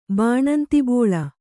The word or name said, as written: ಬಾಣಂತಿಬೋಳ